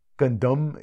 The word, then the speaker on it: Gundam: How the pronunciation Gundam, is that the correct Japanese pronunciation? – Yeah, Japanese Gundam is how it's pronounced.